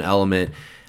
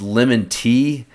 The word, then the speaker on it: LMNT: So L-M-N-T. So when I first saw it, I thought it was pronounced lemon tea. And so I still call it lemon tea, although I think it's supposed to be pronounced element. That is the play on letters there, I guess, if you will.